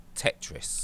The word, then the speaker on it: Tetris: Tetris.